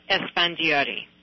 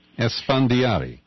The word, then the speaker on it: Esfandiari: I was reading from the upper paragraph here where you're actually being quoted talking about one of the captives, Hale Esfandiara. How do we pronounce that? – Her name is Hale Esfandiari. – Esfandiari. – Yes,